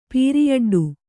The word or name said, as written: ಪೀರಿಯಡ್ಡು